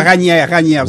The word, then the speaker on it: Raniere: Vous prononcez Raniere. – Ouais. Ben, Raniere, vous avez raison. – Je le prononce à la française.